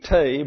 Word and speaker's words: tea: You pronounce T, tay, because that's the French pronunciation and it has to rhyme with obey, you see.